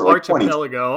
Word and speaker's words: archipelago: Like, he says it's archipelago. Like, I don't know if that's right or not. – I've always heard it pronounced the K-sound.